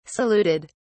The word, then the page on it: saluted: British English